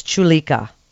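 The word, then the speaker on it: Choulika: So, doctor, am I pronouncing it right? It's Chulika. – That's correct. Perfect.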